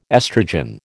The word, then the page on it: estrogen: American English